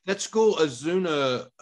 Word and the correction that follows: Azusa: is that how you say it? – Azusa Pacific. Azusa.